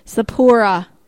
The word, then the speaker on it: Sapporo: Sapporo? – Sapporo.